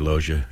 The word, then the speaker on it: Loggia: Loggia. – Robert Loggia.